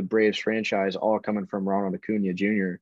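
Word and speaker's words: Acuña: At my three, this is going to make some Braves fans cream their pants, but I got Ronald Acuna Jr. Is that how you pronounce his name? – Ronald Acuna Jr., yeah.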